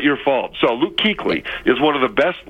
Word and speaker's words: Kuechly: Now, what they did do, though, was they spell his name in a way to confuse basketball fans, because it is pronounced Kuechly, but that's not your fault. I didn't know that. – Because that's a ridiculous way to pronounce that name, I'm just saying.